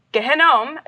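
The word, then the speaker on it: Gehinnom: Um, so Gehenom is how you pronounce it.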